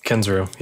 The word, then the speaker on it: Kensrue: Ernie Ball Music Man, Dustin Kensrue, is that how I say his name? Stingray RS. – Yeah, man. Kensrue. Yep.